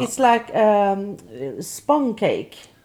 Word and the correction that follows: sponge: How do you say that? – Spong cake. I think you say sponge.